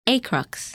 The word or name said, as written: Acrux